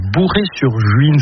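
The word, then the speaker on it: Bouray-sur-Juine: Henri Dubé, aujourd'hui vous vivez à Bourré-sur-Juine. C'est comme ça qu'on prononce l'endroit où vous vivez.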